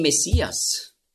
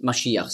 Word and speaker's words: Maschiach: Christus ist einfach die Übersetzung von Hebräisch Maschiach, und Messias, das ist einfach die Aussprache, die Griechen konnten nicht Maschiach sagen, weil es gibt ja kein Sch-Laut im Griechischen. Darum sagen auch die modernen Griechen, die sagen ja nicht Fisch, sondern Fis. Außer sie haben schon gelernt. Also darum wurde aus Masch, wurde Mess, und dann Iach mit dem Het am Schluss, Maschiach, hat man früher ausgesprochen, Maschiach. Da hatten sie auch Mühe, und darum haben sie daraus gemacht Messias.